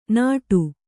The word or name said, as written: ನಡು